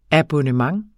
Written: abonnement

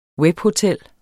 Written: webhotel

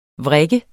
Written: vrik

